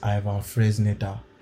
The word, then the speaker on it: Ivan Fresneda: So I want another right back, and the right back I want is Ivan Fresneda from Real Valladolid.